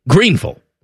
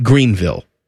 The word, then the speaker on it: Greenville: Yeah, Nicole was yelling at us that we were pronouncing it wrong. Greenville, not Greenville.